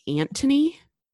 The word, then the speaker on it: Anthony: And I remember like as a child being like, why the fuck is she saying, like why isn't she saying Anthony? And mom was like, because like his name pronounced in a British accent is you would say the TH like a T.